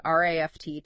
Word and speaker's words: RAFT: We're diving into mindfulness of feeling tones, which is a crucial practice from the RAFT to Freedom program. – Mm-hmm. – And just to be clear, when we say RAFT, we mean RAFT, but pronounced just like the word raft.